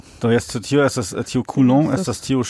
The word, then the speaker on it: Coulomb: Kaj la transferita ŝargo ĉe la fulmoj estas 10,000 kolumb, kai tio... – Coulomb, yes. – Kiel oni legas tion? – Do, mi konas la francan pronuncon Coulomb, estas tio. – Do, mi legus Coulomb.